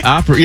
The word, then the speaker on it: Opry: How you say it? Opry? – Opry. Yeah, Opry.